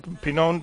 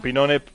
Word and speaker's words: Pinone: Claro, John Pinón pertenece, o John Pinone en nuestra pronunciación.